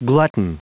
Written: glutton

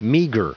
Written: meager

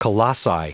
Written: colossi

